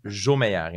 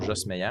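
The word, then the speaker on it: Josmeyer: Donc, ça se prononce Jomeyer et non Jos Meyer.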